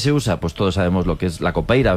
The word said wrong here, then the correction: capoeira: ¿verdad? Capoeira. Qué mal pronuncio últimamente.